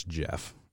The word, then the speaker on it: Geoff: I think that's Jeff is how we're going to pronounce that one. – Geoff Neal. – That is definitely Jeff. – G-E-O-F-F. – Yes, that is a fancy way of spelling Jeff.